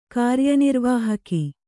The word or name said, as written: ಕಾರ್ಯನಿರ್ವಾಹಕ